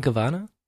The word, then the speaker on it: Gabbana: Isn't it Dolce and Gabbana? – Oh, is it Gabbana? – I think the B is pronounced like a V.